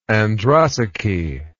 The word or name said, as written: androsace